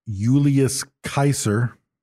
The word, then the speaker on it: Julius Caesar: Julius Caesar was pronounced Julius Kaiser. – Okay. – We're saying it wrong.